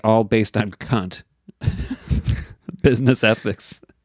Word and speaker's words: Kant: Yeah, he said, this is the German way. The other way is Americanized. – Okay. – This is not how you pronounce his name. You pronounce it Kant.